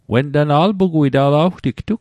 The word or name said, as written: wen tana